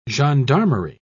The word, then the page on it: gendarmerie: British English